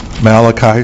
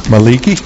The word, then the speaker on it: Malachi: Malachi, sometimes mispronounced Maliki.